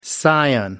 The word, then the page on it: scion: American English